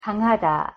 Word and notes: 강하다: Korean

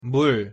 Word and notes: 물: Korean

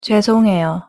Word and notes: 죄송해요: Korean

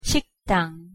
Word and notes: 식당: Korean

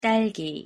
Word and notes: 딸기: Korean

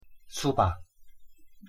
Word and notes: 수박: Korean

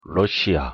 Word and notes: 러시아: Korean

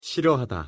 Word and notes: 싫어하다: Korean

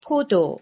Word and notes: 포도: Korean

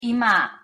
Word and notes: 이마: Korean

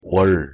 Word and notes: 월: Korean

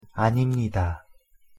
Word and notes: 아닙니다: Korean

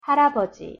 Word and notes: 할아버지: Korean

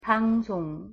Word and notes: 방송: Korean

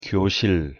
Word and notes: 교실: Korean

